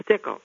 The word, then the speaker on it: Stickle: Now, Stickle is kind of a tough name to live with because so many things rhyme with it, like pickle, like tickle, like fickle.